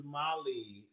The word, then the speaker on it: Mali: No, M-A-L-I is Mali. It's in Africa. Is it actually pronounced Mali? – I believe so.